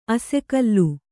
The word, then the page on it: ಅಸಿಗಲ್ಲು: asigallu